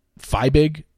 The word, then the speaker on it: Fiebig: Feibig is how you pronounce her last name. I don't know, F-I-E-B-I-G.